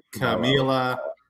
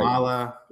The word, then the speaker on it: Kamala: Yeah, a lot of people say Kamala or Camila. – You're right. – Kamala.